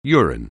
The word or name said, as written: urine